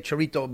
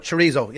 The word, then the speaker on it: chorizo: Well, it depends. I think some of the Spanish would say chorrito, but I think we'd call it chorizo.